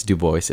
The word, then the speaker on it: Dubois: And the final line is something that has to do with, it's Dubois, it rhymes with voice.